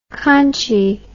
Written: conchie

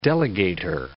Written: delegator

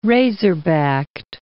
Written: razor-backed

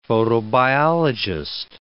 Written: photobiologist

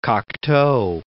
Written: Cocteau

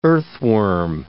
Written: earthworm

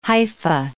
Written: hypha

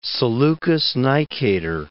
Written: Seleucus I Nicator

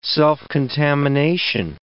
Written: self-contamination

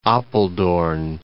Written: Apeldoorn

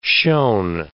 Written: shown

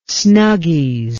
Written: snuggies

